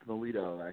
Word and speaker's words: Milito: It's Milito, actually.